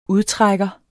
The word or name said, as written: udtrækker